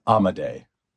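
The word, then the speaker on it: Amodei: How does he pronounce his last name? – I believe it's Amadei.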